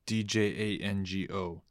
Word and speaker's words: Django: Django. The D is silent.